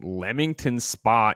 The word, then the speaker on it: Leamington Spa: Playground Limited is a British video game developer based in Leamington Spa, England. – Fuck that means. First of all, you pronounced it correctly. I feel like you were going to say Leamington Spa, but you know what?